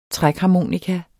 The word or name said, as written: trækharmonika